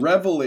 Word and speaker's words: Reveille: Reveille. Is that how Holly Rowe pronounced it? – I think that's how it's spelled. – Reveille. Yeah, R-E-V-E-I-L-L-E. Reveille is known as the...